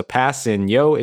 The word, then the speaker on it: Kpassagnon: So Passanio is the way that his last name is pronounced.